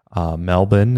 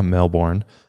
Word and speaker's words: Melbourne: I actually got a chance to go to Australia a few years ago, and I went to Sydney, Melbourne is how it's pronounced, not Melbourne, Melbourne, which is southern Australia.